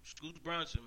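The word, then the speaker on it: Scootz Bronsen: And as usual, man, you can hit me up on Twitter, Instagram, Facebook, SoundCloud, Reverb, YouTube, Scoots Bronson, man.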